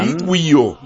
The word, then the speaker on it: Ywio: Iguio. – Iguio. – Cortés. – ¿Vio usted cómo se escribe? Y-W-I-O. – Ah. – Iguio.